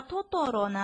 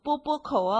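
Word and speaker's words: トトロ: 那以后看到托托罗呢，可千万不要念成波波口哦。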